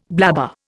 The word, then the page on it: blabber: Indian